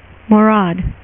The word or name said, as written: marauders